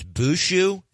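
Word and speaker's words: Bauchou: I pronounced his last name Bachow. PGA Tour Media Communications is telling me it's Bushu, Zach Bushu.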